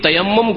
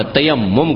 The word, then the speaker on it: তায়াম্মুম: আর এই তায়াম্মুম করাকে, তায়াম্মুম করাকে আমাদের দেশের আবার বিকৃত ভাষায় তয়ম করা বলে, শুনেছেন না, তয়ম করা না তায়াম্মুম করা, শব্দটা একটু উচ্চারণ করতে করছেন, তায়াম্মুম, তায়াম্মুম মানে হচ্ছে উদ্দেশ্য করা।